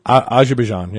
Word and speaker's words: Azerbaijan: Is because there was just the IFBB World Championships, Amateur Championships in the Middle East in Azerbaijan. Is that how you say it? – Azerbaijan, yeah.